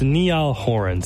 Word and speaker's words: Niall Horan: Slow Hands by Niali Horn. I hope I'm saying it right. Oh, it's Niall Horan. Sorry.